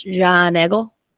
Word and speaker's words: Giant Eagle: Which they, in a Pittsburgh accent, is pronounced John Eagle.